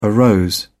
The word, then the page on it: arose: British English